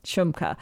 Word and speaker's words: Shmuka: And it's, if I'm understanding, it is a comedy podcast from two comedians in Vancouver named Graham Clark and Dave Shmuka, I think is how you pronounce it. – Shmuka. – Shmuka? Oh, I flipped the U in the, yeah. – in the M. Okay. Shmuka.